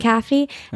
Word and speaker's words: McAfee: McAfee.